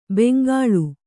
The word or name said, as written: ಬೆಂಗೊಳು